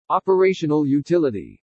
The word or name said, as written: OPERATIONAL UTILITY